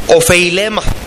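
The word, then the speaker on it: ofeilema (ὀφείλημα): La palabra deudas que usa el Señor Jesús es una palabra griega que se pronuncia ofeilema.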